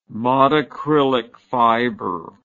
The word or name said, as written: modacrylic fiber